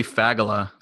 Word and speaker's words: Fagala: You know, sometimes I wish it were pronounced differently, but it's actually Fagala. So you just got to go for it.